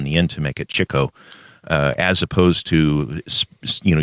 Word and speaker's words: Chico: It's often mispronounced as Chico, but it's actually Chico. That's kind of a little rant that I have. A lot of people pronounce it Chico, but that would be the Hispanic version of the word. And he was Italian, and the common way of pronouncing it would actually be Chick.